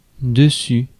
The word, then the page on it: dessus: France; /də.sy/